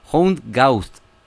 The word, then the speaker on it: Hound Gawd: Hound Gaust, Hound como más o menos suena, sí. – Y Gaust con, bueno, G-A-W-D,